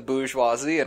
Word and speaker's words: bourgeoisie: I agree, you know, down with the bourgeoisie and all that jazz. – How do you say the bourgeoisie? – Bourgeoisie. – Okay. – Like, with a capital Z-E-E.